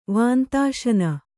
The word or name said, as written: ವಾಂತಾಶನ